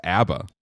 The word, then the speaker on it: ABBA: So my friend Cornelia, who is from Stockholm, pronounces it abba,